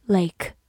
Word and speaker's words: lake: 字母 a 发它本身的音 a，末尾的 e 不发音。 lake，lake，它是一个名词，表示湖、湖泊。比如说西湖就叫做 the West Lake，West 就是西边，the West Lake。